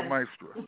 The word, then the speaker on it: maestra: La maestra. – La maestra. When the A's, La maestra. – The vowels are next to each other, are pronounced separately. So it's I-A, maestra.